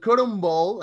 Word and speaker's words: Cotton Bowl: Este se juega acá en Dallas, Texas, en el Cotton Bowl, ah, no sé si se pronuncia así, ahorita que me corrija el productor, eh, Rayados contra el América.